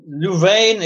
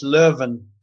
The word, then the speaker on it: Leuven: Uh, Louvain is the French pronunciation, but it's actually in Flanders and it's Leuven.